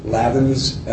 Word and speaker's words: Lavins: Yes, it's John Clancy, C-L-A-N-C-Y, Lavins, L-A-V-I-N-S, and my wife's name is Molly, M-O-L-L-Y.